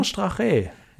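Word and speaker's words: Straché: Ich sag nur Strache. Ich hoffe, das wird so ausgesprochen. – Manon Strache, ja, genau.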